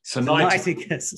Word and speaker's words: Sinaiticus: Sinaiticus.